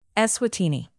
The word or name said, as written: eswatini